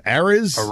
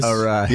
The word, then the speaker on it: Arraez: Arraez. – Yeah. I mean, Jesus Christ. But Luis, how do you say it Arraez. – Again? – Arraez.